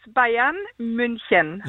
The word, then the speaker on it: Bayern München: Så det er ikke noe hysj, altså det er München. – Ja, kjenn. – Så det uttales Bayern München.